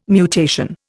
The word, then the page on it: mutation: Indian